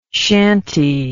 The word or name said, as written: shantey